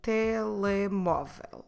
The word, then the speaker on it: telemóvel: The word for mobile or cell phone is, in Portuguese, telemóvel. Telemóvel. Como se escreve telemóvel? T-E-L-E-M, O com acento, V-E-L, telemóvel.